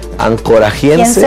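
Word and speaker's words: anchoragense: Porque está Ancorage, pero es Ancorachense, se escribe con G. Ancorajiense. – ¿Quiense? Sí, Ancorajiense. – Ancorajiense. – Ahí está, Ancorajiense.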